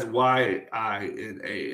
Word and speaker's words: Yina: I watched it on Shudder, and Shudder had I-N-A, and the Google sheet has I-N-A. – Oh, well, IMDb has Y-I-N-A, and that's how they pronounced it, too, was Yi-na.